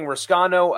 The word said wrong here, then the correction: Rossano: I guess it's Rossano is actually exactly how you say it, regardless of the pronunciation.